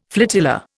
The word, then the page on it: flotilla: Indian